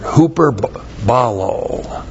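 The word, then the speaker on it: hyperbolo (ὑπερβάλλω): And surpassing, this is a great word, hyperbolo. Hyperbal, long O, hyperbolo. Hyperbolo means to go beyond, to surpass, to outdo.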